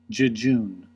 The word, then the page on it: jejune: American English